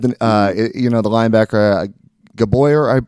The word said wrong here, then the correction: Gabriel: I believe his last name is. I'm going to butcher that last name. – Yeah. Makai Gabriel. – Yep. There you go.